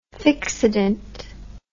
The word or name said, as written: fixodent